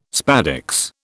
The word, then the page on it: spadix: British English